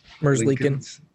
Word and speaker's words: Merzlikins: How do you say his name? Merz. – Merzlikins. Okay. Merzlikins.